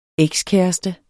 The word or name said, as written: ekskæreste